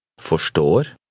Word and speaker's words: forstår: Forstår.